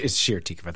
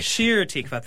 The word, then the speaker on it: Shir Tikva: Prior to joining the clergy team at Emmanuel in 2015 served as assistant rabbi and director of lifelong learning at Hir Tikva Congregation in Minneapolis. Welcome. You minced there. I didn't say that correctly, did I? – It's Shir Tikva. That's okay. – Shir Tikva. Thank you for Hi.